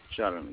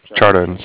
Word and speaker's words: Charan: C-H-A-R-A-N, Charan. – Charan? – Charan, yeah. – Charan. C-H-A-R-A-N? – Right.